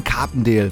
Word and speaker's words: Carpendale: Wayne Carpendale verkörpert, ja.